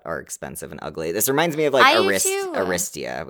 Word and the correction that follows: Aritzia: Was that what it was called? – Aritzia. – Thank you.